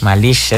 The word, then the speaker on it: Malish: Es que yo estaba aferrada a ponerle acento en Malish. – Malish, es que aquí es el sí, Malish.